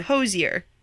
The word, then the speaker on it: Hozier: Hosier. – According Hosier, to Google.